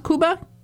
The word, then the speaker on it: Kuba: Is it pronounced Kuba? – Kuba, yep. – The Kuba people, K-U-B-A.